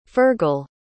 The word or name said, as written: fergal